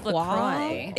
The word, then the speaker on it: LaCroix: Is it LaCroix or LaCroix? – It's LaCroix.